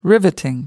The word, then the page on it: riveting: American English